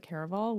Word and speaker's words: Caraval: and she called it Caraval, which means that that's the real name, and I've been saying it wrong when did book one even come out?